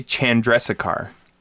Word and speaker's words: Chandrasekhar: J Chandrasekhar. – Chandrasekhar. What did I say? Chandrasekhar? – Chandrasekhar. – I put an extra A in there. Damn it. I put an extra syllable in.